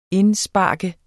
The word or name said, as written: indspark